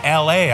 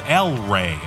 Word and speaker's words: El Rey: Now, I always pronounced it L.A. I didn't realize the city was called El Rey.